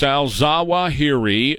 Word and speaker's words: Al-Zawahiri: Al-Zawahiri is how you say his name.